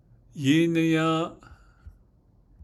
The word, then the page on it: yíníyą́ą́’: 2nd person singular